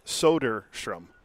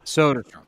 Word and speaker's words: Soderstrom: Yeah, see, in Baseball Reference, on their guide, how do you say his name? It's Soderstrom. So Soderstrom.